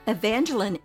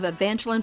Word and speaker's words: Evangeline: This weekly is part one of Evangeline Pratt as a girl and single woman. The last syllable of Evangeline's name is pronounced with a short I, as in Lynn.